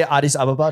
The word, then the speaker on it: Addis Ababa: Did I say Addis Ababa? Did I say that right? – Correctly. Literally better pronounced than most other people. – Christ. – So, like, I was born in Addis Ababa, which is the capital of Ethiopia.